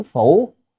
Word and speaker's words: phủ: Đắc sanh giữ phủ, tức là được sanh hay không đó, chữ đây này đọc là phủ.